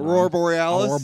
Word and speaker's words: Aurora Borealis: Aurora Borealis.